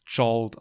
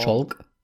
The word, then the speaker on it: Jolt: Und der gute Film wird übrigens Jolt ausgesprochen. – Jolt? Not Jolk? – Nein, nicht Jolt.